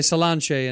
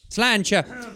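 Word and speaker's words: Sláinte: You say Slánce in Ireland. – Slánce. – Slánce. – Slánce. – Slánce. Slancha.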